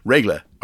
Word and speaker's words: Regla: Regla, R E G L. That's exactly how it's pronounced, or seen, or written.